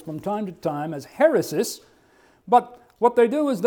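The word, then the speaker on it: heresis (αἵρεσις): Normally I wouldn't put Greek up, but I want to put it up here to illustrate a point, and that is the pronunciation of this word heresis is the Greek term, and that little curved line right there is an H sound. That two letters in Koine and Byzantine and modern Greek is a short E sound. The rho is R, epsilon E, sigma S, iota I, and again sigma S.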